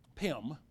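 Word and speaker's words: Pim (פים): Pim, it's P-I-M.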